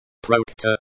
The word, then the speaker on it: Проекта: Проекта, project, genitive case of. Проект. Проект, pronunciation. Проекта, stress on the second syllable.